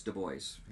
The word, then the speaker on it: Du Bois: Am I saying that right? Du Bois? – Sure. – Du Bois.